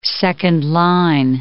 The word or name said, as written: second-line